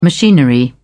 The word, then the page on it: machinery: British English